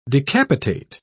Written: decapitate

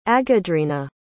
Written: aghadreena

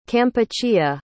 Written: Kampuchea

